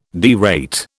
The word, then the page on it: derate: British English